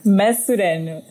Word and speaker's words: Maçorano: So Rita, how do you pronounce your last name? – Maçorano.